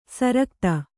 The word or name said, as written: ಸುರಗತಿ